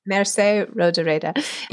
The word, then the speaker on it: Mercè Rodoreda: The, the chapter is titled Merke rogereda Is that right? Is that sound right? Merce, Merce Mercè Rodoreda.